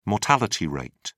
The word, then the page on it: mortality rate: British English